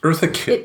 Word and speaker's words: Eartha Kitt: Eartha Kitt.